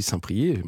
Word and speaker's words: Saint-Priest: On dit Saint-Prié, ça s'écrit Saint-Priest, mais on dit Saint-Prié, maintenant je le sais.